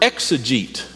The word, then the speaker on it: exegete: Uh, it's the word exegete. At least that's how we would pronounce it in English.